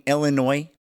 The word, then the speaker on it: Illinois: Number 7, Iowa right now leads number 19, Illinois. The S is silent.